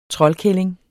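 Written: TROLDKÆLLINGERNE

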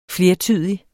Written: flertydig

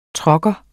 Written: trucker

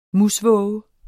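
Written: MUSVÅGEN

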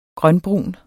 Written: grønbrun

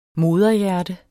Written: moderhjerte

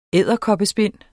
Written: edderkoppespind

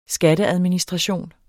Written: skatteadministration

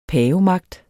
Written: pavemagt

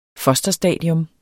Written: fosterstadium

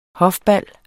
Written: hofbal

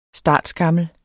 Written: startskammel

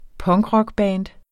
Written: punkrockband

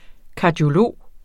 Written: kardiolog

